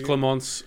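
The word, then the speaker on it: Clémence: I keep pronouncing it Clemence. I didn't actually listen to see if that's how they pronounce the name. I've just kind of zhuzhing it up a little bit because it's French. – I think it is Clemence, and that is a pronunciation that I'm happy with.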